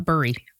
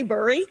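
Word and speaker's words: Bury: And by the way, if you're looking at it on the map, it's B-U-R-Y. It's pronounced Barry, right? Not Burry. – Barry. – Okay. Burry, so you accept both? Okay. – It's pronounced, I mean, I say Burry.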